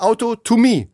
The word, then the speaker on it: Autotomie: Autonomie, Auto. Ah, doch, du hast es nur falsch ausgesprochen. Das heißt eigentlich Autotomie.